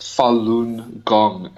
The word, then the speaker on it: Falun Gong: It's pronounced Falun Gong,